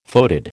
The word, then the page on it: foetid: American English